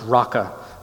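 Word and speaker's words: Raca: It's pronounced raka.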